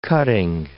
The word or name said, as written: cutting